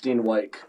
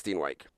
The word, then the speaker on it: Steenwyk: I've heard some people say Steenwyck. – My name is pronounced Steenwyck. – Oh, okay. So it is Steenwyck.